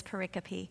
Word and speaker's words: pericope: And in divinity school, they call this a pericope, which is spelled like pericope, telescope, but it's pericope.